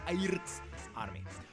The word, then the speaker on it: Airk’s Army: This is exactly how it is pronounced, Airts Army.